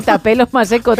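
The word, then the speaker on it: Thapelo Maseko: Tapelo Maseko. – ¡Qué bien lo has dicho! No sé si se dirá así. – Sí, Tapelo Maseko, tal cual. Lo he leído tal cual se escribe.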